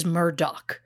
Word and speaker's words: Murdaugh: Based on the spelling of the name, my instinct is to say Murdaugh. But after hearing Alex say his own name, I am going with how he says it, which is Murdaugh.